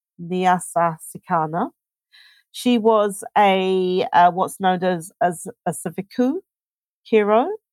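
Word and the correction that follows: svikiro: Sorry, a Savikiro. My pronunciation, I hope it's correct, or a spirit medium of the Shona people.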